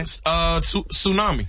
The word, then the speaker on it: tsunami: This has a silent letter in it. – Okay. – Tsunami. – Tsunami. – Tsunami. Yeah. – Tsunami. Sure does. – Now, some people might say tsunami. – Tsunami. – Tsunami. – Yeah. – Somebody might say that. No. They'd be wrong.